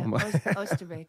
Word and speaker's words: Oosterbeek: We're going to Oosterbeek. Is that how you pronounce it? – Almost, yeah. Oosterbeek.